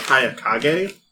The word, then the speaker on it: Hayakage: And then he scribbles down, he signs on your paper, and his name, he goes by Hayakage.